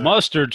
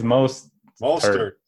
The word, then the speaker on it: Mostert: Mustard sounds good. – Mustard. Let's just make it easy. – I think it's Mostert. – Mostert, I believe, is right.